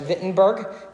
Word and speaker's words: Wittenberg: which I believe is pronounced Wittenberg in German.